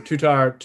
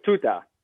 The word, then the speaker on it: Tutar: Ah, true. Tutar. Is that how you pronounce it? – I think so, yeah. – Her name was Tuta, not the monkey. – Tuta. Okay. No, not the monkey. Is it Tuta? The R is like slightly silent.